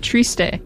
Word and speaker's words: Triste: You know, that's actually really funny because with like the Portuguese pronunciation, it would be Triste, which means the same thing in French as it does.